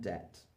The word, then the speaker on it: debt: It is also generally silent before the T at the end of a root word. Subtle, doubt, and debt.